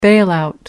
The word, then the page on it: bailout: American English